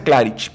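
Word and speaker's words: Klarić: Difiero y dije, y si no, búsquenlo en YouTube, Jürgen Klarik, con K se pronuncia Clarich, pero es Claric, se escribe.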